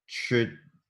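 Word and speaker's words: trdelník: Is it Trelnik? – Ah, yes, it's Trdelnik. – Trelnik. It's spelled T-R-D-E-L-N-I-K. – Yeah. – Trelnik. – I think it's a J sound. Trdelnik. Something like that.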